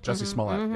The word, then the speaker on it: Jussie Smollett: Jussie Smollett. – Jussie Smollett.